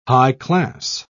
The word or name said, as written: high-class